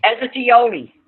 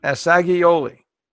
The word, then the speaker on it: Assagioli: Pronounce his last name again for us. – Acciaiuoli. – Assagioli.